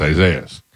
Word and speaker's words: Isaias: And then it was spelled out phonetically, E-E-S-A-H-E-E-A-H-S. – And right, like, it's spelled phonetically, it's Isaias.